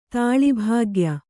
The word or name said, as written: ತಾಳಿಭಾಗ್ಯ